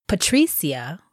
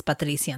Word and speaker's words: Patricia: But when I say Patricia, which is phonetically pronounced in English versus Patricia in Spanish, people don't chop it up. So I like Patricia.